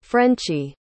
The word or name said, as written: frenchie